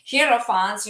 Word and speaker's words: Hierophant: Were taken by the Hierophants, Am I pronouncing properly? Hierophant? Yeah. Hierophants, you know, to this sarcophagus, and then they perform certain rituals and possibly give them some drugs as well, right? – Yeah, that's part of it.